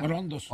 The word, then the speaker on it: orondos: eso sí lo enaltecen y se ponen orundos, sí se dice así, orundos, ¿no? O sea, orondos.